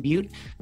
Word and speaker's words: Beute: So if you search Ethan Butte, I'll probably be the only one who comes up.